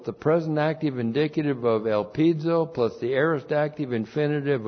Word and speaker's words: elpizo: The post-positive conjunction de is the conjunction of contrast, correctly translated but, and then we have elpizo. Elpizo. Looks like that in the Greek, E-L-P-I-Z, long O.